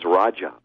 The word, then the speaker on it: Raja: Okay, Satyen, and it's spelled S-A-T-Y-E-N. And my last name is Raja, R-A-J-A.